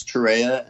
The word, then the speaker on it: Torreya: One is Torreya and one is Torreya. And it's a regional thing.